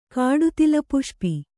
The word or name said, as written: ಕಾಡುತಿಲಪುಷ್ಪಿ